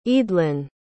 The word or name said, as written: eadlyn